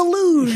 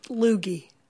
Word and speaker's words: luge: Here's some fan of the luge. – Here's him doing skeleton. – Sure. – Luge, by the way, I always pronounced it loogie.